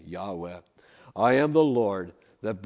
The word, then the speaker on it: Yahweh: In Hebrew, it's just those four letters. We usually pronounce it Yahweh if we try to pronounce it in English.